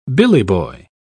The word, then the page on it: billyboy: British English